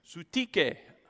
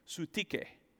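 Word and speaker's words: Syntyche: Now I appeal to Euodia, and I'm going to try to say this. Sutike. I had to look this up. That's how you pronounce it from the original language. Sutike.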